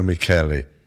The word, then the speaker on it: Michele: Alessandro Michele. – Michele? An Irish name? – No, he's not. No, M-I-C-H-E-L-E, but like Michelle, but they pronounce it.